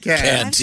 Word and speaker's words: Kant: Oh, and then, yes, Tommy, it's pronounced Kant.